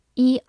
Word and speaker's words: eight: Eight, eight, ei 字母组合发 a，g h 不发音，t 发 t，eight eight，它是一个数词，表示八。